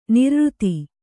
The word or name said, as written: ನೈರ್ಋತ